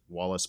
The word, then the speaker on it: Walas: It's like Wallace, but it's not. W-A-L-A-S.